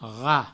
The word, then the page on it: rat: provençal